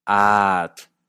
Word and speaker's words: atl: El náhuatl clásico poseía vocales largas y cortas, como en atl, agua, atl. Poseía un golpe glotálico, como en atle, nada, atle, atle.